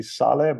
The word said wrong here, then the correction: Chalé: but Chalé. – Chalé. Yeah. You learn everything new, something new every day.